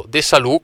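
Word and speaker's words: Desalu: perché è l'allenatore di Fausto De Salù, con rigoroso accento sulla U finale, come mi ha detto Fausto, campione olimpico della 4% ai Giochi di Tokyo, una giornata veramente che tutti ricordiamo con grande passione.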